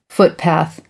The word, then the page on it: footpath: American English